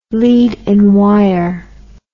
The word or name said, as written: lead-in wire